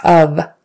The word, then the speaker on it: of: It's of. That's a short U sound. Of. Even though it's spelled O-F, we say it short U-V. Of. So not F with an F sound on the end, even though it's spelled with an F.